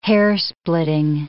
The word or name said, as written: hairsplitting